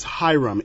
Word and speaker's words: Hiram: My name is Hiram. It's spelled H-I-R-A-M. – H-I-R.